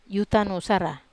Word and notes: utanusara: Wamesa

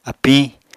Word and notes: apiñ: Apatani

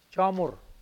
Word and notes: joahmwur: Mokilese

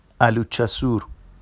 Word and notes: aluʧʧasur: Vafsi

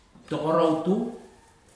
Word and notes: tokorau tu: futuna aniwa

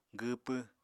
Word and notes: gwwpw: apatani

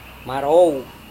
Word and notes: marou: Serewen

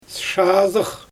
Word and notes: ʃaazɯx: Xyzyl

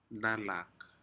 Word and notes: nalak: Aneityum

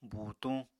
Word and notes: buutuñ: apatani

